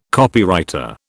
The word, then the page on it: Copywriter: British English